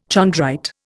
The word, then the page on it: chondrite: Indian